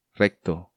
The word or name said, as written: Recto